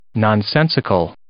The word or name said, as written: nonsensical